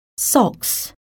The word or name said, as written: socks